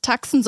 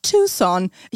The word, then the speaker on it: Tucson: Äh, die von mir als Tucson ausgesprochene Stadt in Arizona heißt nicht Tucson, sondern Tucson.